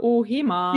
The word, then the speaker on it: Ohema: Also oh, es ist oh. ein offenes O, so wie O und dann Ohema und kurz. Ohema.